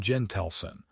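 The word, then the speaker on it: Jentleson: Strategist Adam Gentelson, pronounced J-E-N-Tel-Sun, suggests picking someone who already ran in the primaries to keep things looking legitimate.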